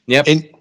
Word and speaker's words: NYAP: I don't know how you guys pronounce this, but Nyap or Nyap, is that, how do you say that? Is that how you say it? Nyap? – Nyap? N-Y-A-P, not your average popper.